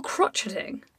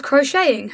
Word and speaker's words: crocheting: crocheting. Or crotcheting. – I think it's pronounced crotcheting. – Is it? – No. – Oh. who was crocheting, her hands constantly moving, was transfixing the tot.